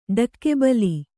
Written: ಡಕ್ಕೆಬಲಿ

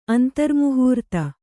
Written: ಅಂತರ್ಮುಹೂರ್ತ

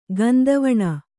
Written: ಗಂಧವಣ

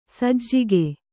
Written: ಸಜ್ಜಕ

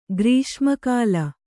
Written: ಗ್ರೀಷ್ಮಕಾಲ

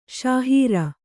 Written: ಶಹರು

